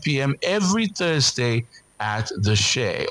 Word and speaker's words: Che: Of course, Shay is a bar and a grill here in Abuja. It is spelled C-H-E, so make sure you go there for good vibes only.